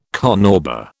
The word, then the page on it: carnauba: British English